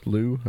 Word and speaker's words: Looe: They were from, I guess it L-O-O-E, Lou, I guess.